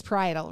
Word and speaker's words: parietal: I always want to say this wrong, but it's parietal, right? – Yep.